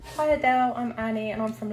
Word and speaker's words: Adele: He came and asked me how I say my name, and I was like, Adele.